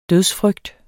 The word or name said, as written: dødsfrygt